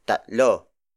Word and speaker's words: tatlo: The next word is tatlo. Tatlo. Tatlo. Tatlo is spelled as T A T L O.